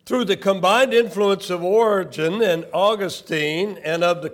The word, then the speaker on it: Origen: And two major figures, we can look back and see, two major figures in regard to that, and that is Origen. That's pronounced Origen.